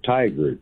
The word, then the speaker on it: Tigard: It's called Tigard, Oregon.